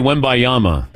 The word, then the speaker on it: Wembanyama: When you say Wembayama, that gets me excited. – no, when I say it correctly.